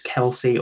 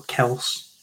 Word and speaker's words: Kelce: Moving on to the tight end position, Travis Kelce, or Kels, whichever one you want to pronounce it as.